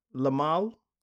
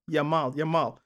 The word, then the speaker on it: Yamal: Why is it Lamal? – Yamal.